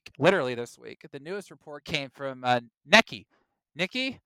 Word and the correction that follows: Nikkei: how do you say that, Will? – Nikkei, thank you. Nikkei, I like that.